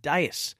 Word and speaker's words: dais: Andrew said, can you just take us over how you pronounce the word dais again? Did I say days? I reckon I might have said days. But you're right, Andrew. Dais. Thank you very much.